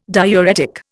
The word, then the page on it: diuretic: Indian